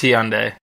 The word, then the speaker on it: Hyundai: Did you say Hyundai? – Yeah, is it Hyundai? I don't know how to say it. Yeah, it's Hyundai. Yeah.